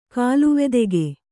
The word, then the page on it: ಕಾಲುವೆದೆಗೆ: kāluvedege